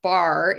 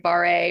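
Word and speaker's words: Barre: And it is if your listeners have heard of Bar, it's B-A-R-E, I always spell it because some people think it's like bar A, or it's a ballet bar workout that uses the bar for leverage and support.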